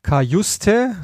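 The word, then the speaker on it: Cajuste: Die haben sich Kajuste, so spricht man den aus, ausgeliehen vom SSC Neapel fürs Mittelfeld.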